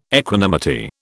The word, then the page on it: equanimity: British English